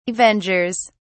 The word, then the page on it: avengers: British English